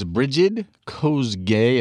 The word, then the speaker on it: Brigid Kosgei: And her name was Brigid Kosgei, if I'm pronouncing that correctly.